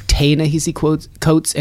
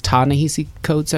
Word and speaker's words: Ta-Nehisi Coates: I think I always say Ta-Nehisi Coates, and it's Ta-Nehisi Coates, I believe.